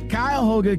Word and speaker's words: Cuyahoga: Like, for example, man, I've been saying it wrong for the longest. It is Cuyahoga Community College Transportation Innovation Center, all right?